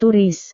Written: turis

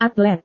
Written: atlet

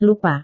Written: lupa

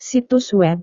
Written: situs web

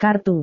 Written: kartu

